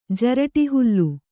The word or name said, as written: ಜರಟಿಹುಲ್ಲು